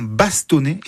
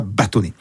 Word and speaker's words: bastonner: D'ailleurs, dans son dictionnaire provençal et français de 1728, Sauveur Adrien Pélas prescrivait prononcer l'S, donc dites bien bastonner et pas bâtonner.